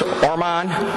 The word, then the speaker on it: Orman: Did I pronounce that right, Bruce? Orman? I don't know. She's not a baseball person. What's that? Okay, Orman.